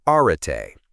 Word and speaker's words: Arete: This is the core premise of Brian Johnson's monumental work, Arete, pronounced Arete.